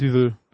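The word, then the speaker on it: Duvel: Wie auch immer es ausgesprochen wird. Düvel, Deuvel. – Ich bin da mal überlegt. Düvel.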